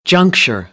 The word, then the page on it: juncture: American English; ɚ